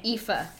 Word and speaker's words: Aoife: O-F-A? – F-A. Aoife. – Aoife.